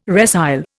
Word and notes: resile: Indian

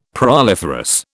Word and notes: proliferous: British English

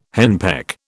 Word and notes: henpeck: British English

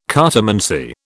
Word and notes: cartomancy: British English

